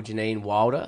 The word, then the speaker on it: Janine Wilder: We're here with the wonderful Janine Wilder.